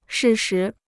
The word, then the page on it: 适时: shì shí